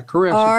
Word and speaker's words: charisma: The word for gift in the Greek is charism. – I think it's is it charisma? – Oh, is it? Yeah. – C-H-A. – Oh, yeah, charisma. – R-I-S-M-A is charisma.